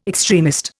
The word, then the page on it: extremist: Indian